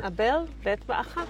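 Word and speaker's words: Abel Beth Maacah: How would you pronounce that? Is that correct? Or Abel Bet Macha. – Yes. Macha, great.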